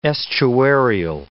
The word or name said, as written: estuarial